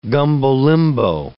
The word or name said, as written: gumbo-limbo